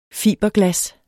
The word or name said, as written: fiberglas